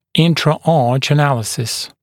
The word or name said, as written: intra-arch analysis